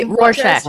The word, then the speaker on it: Rorschach: Is that how you say it? – The Rorschach. Rorschach. Yeah, yeah, Thank you.